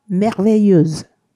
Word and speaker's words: merveilleuse: え男性系の方が Merveilleux 女性系は Merveilleuse です。